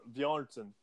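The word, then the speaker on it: Björnsson: Bjornsson. – Bjornsson.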